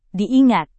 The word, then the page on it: diingat: Indonesian